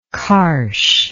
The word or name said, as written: karsh